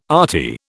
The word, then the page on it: arty: British English